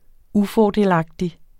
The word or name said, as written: GYLDNET